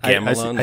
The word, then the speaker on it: Gamelon: Gamelon? Am I saying that right? – I don't know. Gamelon? – I'd say like Gamelon, like Camelot. Yeah.